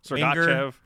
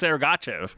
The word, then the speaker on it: Sergachev: Sergachev, Sergachev. Yeah, it literally says S-A-I-R-G-A Chev. So it's like, it's Sergachev. However you want to do it, but literally in the NHL pronunciation guide, it's Sergachev.